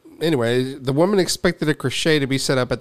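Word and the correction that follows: crèche: It's just pronounced crash.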